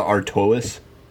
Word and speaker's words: Artois: You didn't even Stella pronounce Artois. All the letters in that word. That's some high class shit right there. – Yeah, it's clearly Stella Artois.